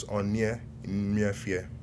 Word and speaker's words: Onye Nmefie: So that is basically pronounced as Onye Nmefie.